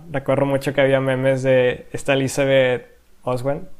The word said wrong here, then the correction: Olsen: si lo, si lo dije bien su apellido. Olsen. Olsen. Elizabeth Olsen.